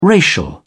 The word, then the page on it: racial: British English